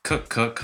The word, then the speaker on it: Kuckuck: Her name is Mary Kuckuck. Hope I said that correctly. – You got it.